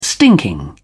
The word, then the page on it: stinking: British English